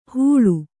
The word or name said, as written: ಹುಳು